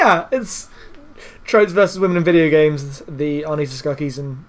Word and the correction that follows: Sarkeesian: I forget what Anita Sarkeesian. – Sarkeesian. I put the K too early.